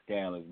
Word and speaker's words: Scanlouz: You got the first part right. It is Scanlouz, but the last name is pronounced Deleon.